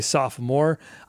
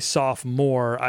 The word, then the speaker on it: sophomore: Some people say sophomore. I don't say that. I say sophomore. I think it's a silent O.